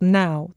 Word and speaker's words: Nao: And the diary was written by a Japanese teenager called Nao, that is spelled N-A-O. But of course, I think you're meant to hear Now, N-O-W as well.